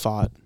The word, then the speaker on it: Pfaadt: I think it's Fod. – Fod. – It's something like that. – Something. It's spelled P-F-A-A-D-T. – Silent P. – Yeah, silent P.